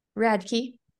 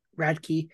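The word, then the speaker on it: Radtke: Charles Radke. – Radke. The TKE threw me off, that's why.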